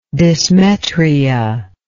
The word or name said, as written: dysmetria